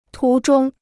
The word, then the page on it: 途中: tú zhōng